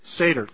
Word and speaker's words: Seder: And so today, when people do a Seder service, is that how you say it? Seder. Thank you. I knew something wasn't quite right there.